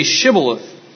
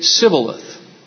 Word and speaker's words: Shibboleth: Then they would say to him, then say Shibboleth. And he would say Sibboleth. He couldn't pronounce the sh sound.